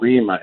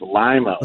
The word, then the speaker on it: Lima: Well, tell Travis's parents that I corrected Adam for saying it's not Lima, it's Lima, Ohio.